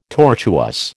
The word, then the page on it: tortuous: American English